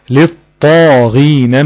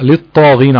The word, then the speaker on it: للطاغين: لا اللام التانية دي مدغمة. اللام التانية مدغمة. ليه مدغمة؟ عشان اللام شمسية صح؟ يبقى حكمها الإدغام. يعني هي أصل الكلمة كانت الطاغين ألف ولام ودخلت عليها اللام فحذفت همزة الوصل ولكن اللام التانية دي لام شمسية حكمها الإدغام. تمام؟ فبنطق للطاغين على طول والطاء طبعا مشددة عشان الإدغام.